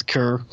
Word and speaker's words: Keur: But I found it interesting in quotes from both Ryan Kerr, whose name I always mispronounce, Kerr, K-E-U-R.